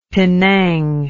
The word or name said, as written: pinang